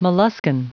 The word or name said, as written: molluskan